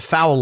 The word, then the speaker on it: Fowl: He's the host of The Foul Life. – I'm way off, Jimmy. – Which, yes, you are. – Way off. – But it's spelled F-O-W-L, like as in bird.